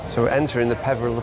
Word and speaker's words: Peveril: Well, we're gonna move down the road from the Britain's Protection to go to somewhere called the Perivale. – The Perivale of the Peak. – Hello there, Update Boy here again. It's Peveril of the Peak, not Perivale, as they mispronounced just then.